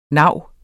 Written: navene

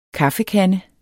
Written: kaffekande

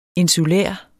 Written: insulær

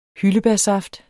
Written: SAFTS

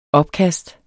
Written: opkast

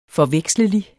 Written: forvekslelig

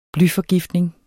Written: blyforgiftning

